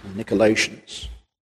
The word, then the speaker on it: Nicolaitans: Nicolaitans, some would pronounce it, Nicolaitans.